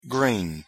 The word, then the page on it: green: English